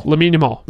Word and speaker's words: Lamine Yamal: Lamine Yamal.